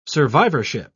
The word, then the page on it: survivorship: British English